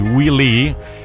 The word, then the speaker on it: Wile: che è uno dei creatori del di Wile-E, che perché in realtà è Wile-E. – Wiley. – Incredibile, che Wiley puntato sarebbe in originale. Wile, che si pronuncia come mentre, diciamo.